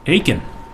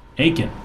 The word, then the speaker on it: Eakin: Aiken. Aiken. Rhymes Aiken. With bacon. – Aiken. – E-A-K-I-N.